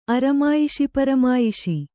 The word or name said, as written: ಅರಮಾಯಿಷಿಪರಮಾಯಿಷಿ